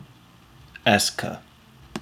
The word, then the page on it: est-ce que: American English